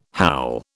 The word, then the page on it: howl: British English